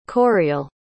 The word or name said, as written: coireail